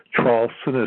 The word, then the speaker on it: Traulsen: If you simply Google my last name, Traulsen is spelled T like Tom, R-A-U-L-S-E-N like Nancy, then put the word and, A-N-D, and then Trump, T-R-U-M-P, and the word whistleblower.